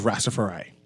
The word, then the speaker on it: Rastafari: Just now, we had members of the Royal Bahamas Police Force on, but now we have some representative from the host of Rastafari.